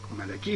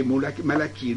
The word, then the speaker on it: Malachi: Hein, Malachi, il faut prononcer Malachi et non pas Malachi. Parce qu'il faut suivre là ce que dit l'hébreu.